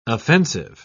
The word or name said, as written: offensive